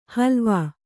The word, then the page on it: ಹಲುವ: kannada